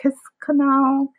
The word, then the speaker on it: Kiskanu: Is it Kiss Canoe? Kiss canoe. Okay. Don't want to mispronounce no. anything. Kiskanu is it.